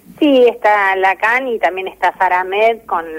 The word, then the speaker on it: Lacan: lo dije bien, Lacan. – no, ah, Lacan. porque acá hay un profe francés que me reta.